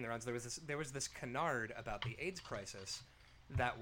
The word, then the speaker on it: canard: It's French, so apparently it's canard.